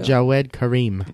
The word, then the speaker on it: Jawed Karim: I can't pronounce this name, but whatever. – It's Jawed Karim.